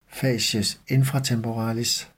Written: facies infratemporalis

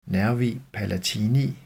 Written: nn. palatini

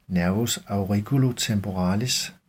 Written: n. auriculotemporalis

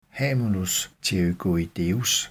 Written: hamulus pterygoideus

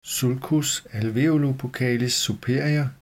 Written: sulcus alveolobuccalis superior

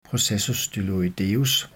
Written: processus styloideus

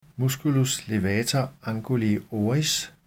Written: m. levator anguli oris

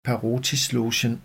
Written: parotislogen

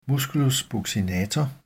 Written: m. buccinator

